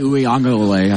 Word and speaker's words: Uiagalelei: Because that Clemson team, the understanding is from all of us, is that DJ Uiagalelei, I said it correctly. – Well done. – That was good.